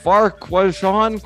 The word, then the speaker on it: Farquharson: You got the best name in League Two in Priestley Farquharson. How do you say his name? – Farquhard, right? – Farquharson. – Farquharson.